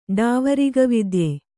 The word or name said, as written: ಡಾವರಿಗವಿದ್ಯೆ